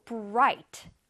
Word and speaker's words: bright: Oh, bright. Bright, 밝은 나왔네요. The light bulb is bright. 지금 전구가 굉장히 밝지요. 자, 밝은은 영어로 bright, bright. 자, 지금 보니까 스펠링이 B-R-I-G-H-T죠, 그쵸? I-G-H가 모여지면 I 소리가 납니다.